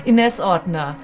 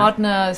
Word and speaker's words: Ordner: Inez Ordner. – Ordner. – Ordner is Austrian, and Inez is my Spanish name.